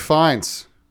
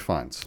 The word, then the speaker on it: Fiennes: So written by Seth Reese and Will Tracy, starring Ralph Fiennes. Yes, that's how you pronounce it. Ralph Fiennes.